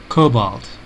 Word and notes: cobalt: American English

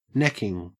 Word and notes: necking: Australian English

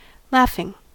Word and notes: laughing: American English

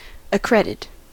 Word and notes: accredit: American English

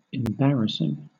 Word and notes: embarrassing: British English

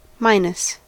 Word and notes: minus: American English

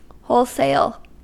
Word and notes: wholesale: American English

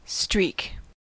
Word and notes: streak: American English

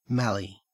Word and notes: mallee: Australian English

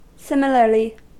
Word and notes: similarly: American English

